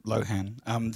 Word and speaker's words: LXHXN: No, you just pronounce it Lohan. The X's are there to stop people with legal interests finding it on Google.